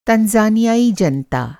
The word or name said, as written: तंजानियाई